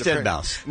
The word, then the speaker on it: Deadmau5: I know, but he goes by Deadmau5. – Here it says Deadmau5. – I know it's spelled, but he just... – Well, if it's spelled this way, then it should be pronounced Deadmau5. – Deadmau5. – But why? How would you say that when there's a 5 at the end of it? – He's being creative. I don't know. But it's just Deadmau5.